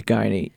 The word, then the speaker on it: Guiney: So the name of my podcast is Guyney Sports Pod. My last name is Guyney, and it's spelled G-U-I-N-E-Y.